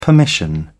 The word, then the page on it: permission: British English